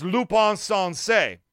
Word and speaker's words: Lupin Sansei: But you gotta excuse me if I don't refer to him the Japanese way as Lupin Sansei. Because, yes, that is officially the correct way to say it, but that's not the American Ray way to say it.